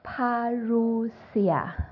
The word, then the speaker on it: Parousia: 看英文哈，Parousia。